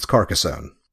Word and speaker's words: Carcassonne: Now, there's some debate on how to pronounce the game. Carcassonne? To set the pronunciation record straight, it's Carcassonne. The game is simple in design, but nearly infinite in repeat playability.